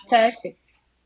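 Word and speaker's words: Cēsis: Texas. – Almost, because like when you, the first letter C in Latvian, it's te, so I will say tesis, but you're saying it with S like tesis.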